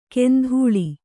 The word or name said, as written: ಕೆಂಧೂಳಿ